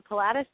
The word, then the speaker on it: Pilatus: It was a Pilatus, is that how you say it, PC-12? – Yeah, you said that just right. It's a Pilatus PC-12NG,